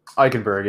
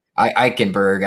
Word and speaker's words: Eichenberg: Continuing on here, we have our next offensive tackle here in Liam Eichenberg. Is that I say that right? – Eichenberg, yeah. – Eichenberg out of Notre Dame, 6'6", 305.